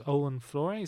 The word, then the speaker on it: Olwen Fouéré: Uh, however, that's broken by the arrival of Catherine, uh, who's Olwen Fouéré.